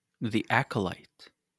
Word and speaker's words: The Acolyte: The Acolyte. I'm pronouncing that right. – Yeah.